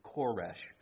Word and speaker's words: Koresh: In Hebrew, the name is Koresh, but we anglicize it to Cyrus.